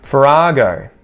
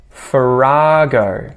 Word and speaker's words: farrago: Farrago. Farrago. Notice where the emphasis is. Second syllable.